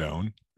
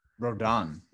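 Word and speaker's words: Rodón: It's Carlos what? Rodon. – Rodon, right? – Rodon, right? – Yeah. R-O-D-O-N with a Rodon, yeah.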